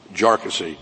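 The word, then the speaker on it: Jarkesy: By the way, it's pronounced Jarkissi, not a number of other ways that it's been pronounced by many.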